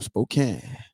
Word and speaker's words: Spokane: And Victor says, coming from Spokane. What's that? Am I saying that right? – Yep, coming from Spokane.